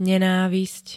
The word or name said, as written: nenávisť